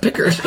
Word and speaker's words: Picard: Picard. – It's pronounced Picker. – Oh. I've been making an idiot out of myself.